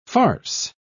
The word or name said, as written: farce